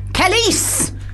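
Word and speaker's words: Kelis: Kellis Did I say it right? – You did. The Kelly's. Emphasis very much on the second syllable of Khaleesi's name.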